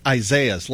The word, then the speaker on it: Isaias: I think it's Isaias, like Isaiah plural, but it's spelled differently.